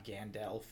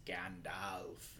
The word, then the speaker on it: Gandalf: And he's like, it's not Gandalf, it's Gandalf.